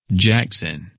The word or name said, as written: Jackson